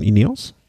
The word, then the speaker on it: Ineos: Ineos? – Mhm.